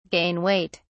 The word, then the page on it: gain weight: British English